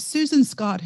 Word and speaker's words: Susan Scott: And is it Susan Scott who wrote Fierce Conversations? Am I saying her name correctly? Yeah. Yes.